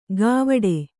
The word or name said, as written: ಗಾವಟೆ